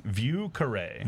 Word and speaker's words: Vieux Carré: Vieux Carré.